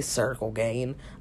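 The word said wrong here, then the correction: Ciryl Gane: I meant Searle Gain.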